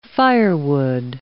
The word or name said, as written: firewood